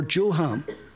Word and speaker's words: Joram: So there's now two kings, one in Israel, one in Judah, and both of them are called Jehoram or Joham, whatever way you want to pronounce it. Now, in the fifth year of Joram,